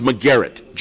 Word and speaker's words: McGarrett: Well, that's how you say it's McGarrett, just like Steve McGarrett. Don't try to funk it up with something stupid.